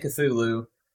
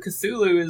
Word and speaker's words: Cthulhu: But, no, actually, I'm pretty sure Cthulhu is the canon pronunciation.